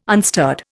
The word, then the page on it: unstirred: Indian